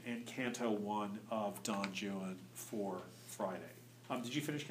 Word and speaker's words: Juan: The way, who cares how the Spanish pronounce it, I'm English, on Juan, so Juan rhymes with new one and true one and blue one and so on.